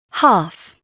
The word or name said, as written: half